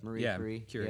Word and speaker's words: Marie Curie: Marie Curie.